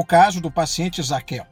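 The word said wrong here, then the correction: Zaqueu: Aliás, Záqueo é a pronúncia correta.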